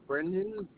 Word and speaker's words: Brendan: See, so Brendan, B-R-E-N-D-A-N, last name Malave, M-A-L-A-V as in Victor, E.